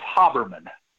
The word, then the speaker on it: Haberman: My last name is pronounced Haberman.